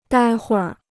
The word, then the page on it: 待会儿: dāi huìr